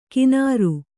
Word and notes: ಕಿನಾರು: kannada; kināru

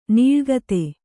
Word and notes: ನೆಲಗತ: kannada

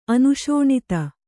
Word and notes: ಅನುಶೋಣಿತ: Kannada

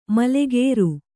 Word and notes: ಮಲೆಗೇರು: male gēru

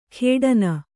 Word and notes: ಖೇಡನ: khēḍana

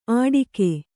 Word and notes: ಅಡಿಕೆ: kannada

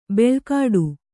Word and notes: ಬೆಳ್ಕಾಡು: beḷkāḍu

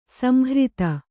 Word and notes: ಸಂಹೃತ: samhřta

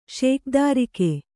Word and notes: ಶೇಖದಾರಿಕೆ: śekadārike

